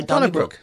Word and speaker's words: Donnybrook: Yeah, well, it's pronounced the same way we say Donnybrook.